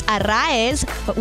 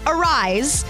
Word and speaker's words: Arraez: Luis Arraez, which is how you say it, guys, not Arise.